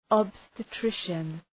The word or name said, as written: obstetrician